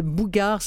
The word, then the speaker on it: Mbougar: Donc, Mohamed Bougar, ça s'écrit M-B-O-U-G-A-R, mais j'ai toujours entendu les Sénégalais dire qu'il fallait pas prononcer le M et SAR, S-A-2-R.